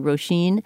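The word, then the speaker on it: Róisín: Okay, well, she was one musician that we talked about whose name we apparently mispronounced because it's one of those, you know, Irish names that's spelled quite differently from how it's pronounced. It's actually Roisin.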